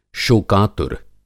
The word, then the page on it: शोकातुर: Hindi